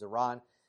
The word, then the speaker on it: Iran: Sorry, I know some people pronounce it, but the proper pronunciation is Iran.